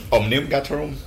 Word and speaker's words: Omnium Gatherum: Omnium Gatherum.